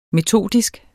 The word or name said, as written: metodisk